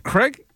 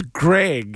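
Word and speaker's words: Graig: Greg. – Greg. Craig? – Kraft. – That's a G. – Yeah. – The G, first of all, wild way of spelling it. – Greg. – It's Greg.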